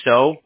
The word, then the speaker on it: Seo: My last name is pronounced so, but I spell it S-E-O.